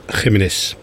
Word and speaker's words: Jiménez: Flaco Jiménez. Zo moet je het uitspreken, hè, geloof ik. – Ja, Jiménez.